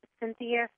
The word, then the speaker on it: Cynthia: And for everyone listening, if you want to get a hold of me, my website is Cynthia, C-Y-N-T-H-I-A. Last name is it's Sloan, but it's spelled S-L-O-N.